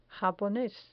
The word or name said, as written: japones